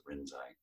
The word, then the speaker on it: Rinzai: Rinzai, R-I-N-Z-A-I, Rinzai. – Okay, I will look up and educate myself after this talk. – In Chinese, it's Linji, and so sometimes you have to look that up, but we still just honor the Japanese pronunciations of Rinzai.